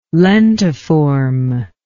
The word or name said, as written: lentiform